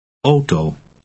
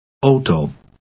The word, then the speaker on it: auto: Auto. – In words derived from the French, AU is pronounced O, as in the expression, lo and behold. Read 2 aloud. – Auto.